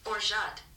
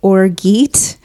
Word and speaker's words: orgeat: Orgeat. So, you pronounce it orgeat. The word that you couldn't say that looks like orgeat. It's orgeat.